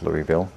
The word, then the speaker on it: Louisville: He writes, in Louisville, I think it's pronounced Louisville, Louisville. In Louisville,